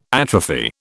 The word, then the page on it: atrophy: British English